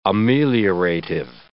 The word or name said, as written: ameliorative